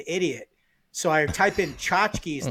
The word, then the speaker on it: tchotchkes: So tchotchkes. I found out they're called tchotchkes. It's spelled with a T. – Like a Polish? – Yeah, it's like T-C-H. There's like two T's in it.